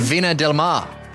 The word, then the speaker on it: Viña del Mar: Viña del Mar.